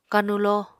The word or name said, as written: kanulo